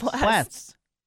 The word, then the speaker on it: blasts: You got to hit the first S and the second S. – Blasts. – It's now, like wasps. – Say it like wasps.